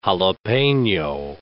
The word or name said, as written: jalapeno